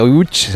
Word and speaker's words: Łódź: Aunque se pronuncia Łódź, se escribe Lodz, por si alguien lo busca Lodz, casi no tiene nada que ver la pronunciación.